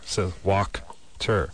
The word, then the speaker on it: Wachter: It's phonetically, it's W-O-K-T-E-R. So Wachter.